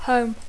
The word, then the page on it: home: English